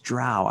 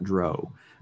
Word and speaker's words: drow: And it is pronounced drow. I've heard other people pronounce it other ways. I grew up saying drow, and there's a great pronunciation guide on D&D Beyond, and it is drow, not drow.